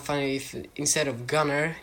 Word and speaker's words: Gunnar: It's a very weird name, and it's actually Swedish, and because I live in Sweden, I know how it's pronounced, and it's pronounced Gunnar.